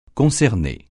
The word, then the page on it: concerner: francais